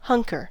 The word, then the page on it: hunker: American English; /ˈhʌŋkɚ/